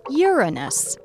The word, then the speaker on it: Uranus: But 70 years later, even England agreed on the name Uranus, after the Greek god of the sky. And yes, that's the correct pronunciation, though giggling grade schoolers of our enlightened era will beg to differ.